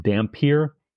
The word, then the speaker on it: dhampir: The folkloric word for that is, I think it's pronounced dampier, D-H-A-M-P-I-R.